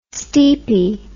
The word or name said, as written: steepy